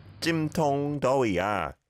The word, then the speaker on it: 찜통더위야: 오늘은 찜통더위야. – From the top one more time. – 와, 오늘은 찜통더위야. – 찜통더위야. Good pronunciation. Tong, tong, the O vowel sound.